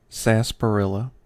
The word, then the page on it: sarsaparilla: American English